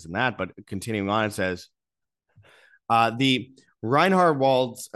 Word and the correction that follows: Reinhardswald: it's hard to pronounce, it's Reinhardswald is sparsely populated,